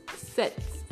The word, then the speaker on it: sept: 7 is sept. It's pronounced like S-E-T in English, set, like to set the time or set something, exactly like that.